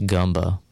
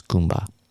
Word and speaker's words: Gumba: I thought it might have been pronounced Gumba, but they actually had a pronunciation guide. It's Goomba. It's spelled G-U-M-B-A.